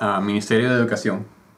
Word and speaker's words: Ministerio de Educación: Ministerio de Educación. – Educación. – Ministerio de Educación.